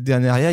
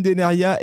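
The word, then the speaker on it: Dénériaz: Et vous remarquerez que Deneria, il y a un Z à la fin. Ça ne se prononce pas, chers amis. Voilà, comme ça, vous le saurez. Antoine Deneria, eh bien, il est haut savoyard, et champion olympique de descente en 2006 à Turin.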